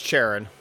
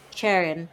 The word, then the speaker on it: Charon: Oh, yeah, it's Charon. – Charon. And I think, one, his name's Charon.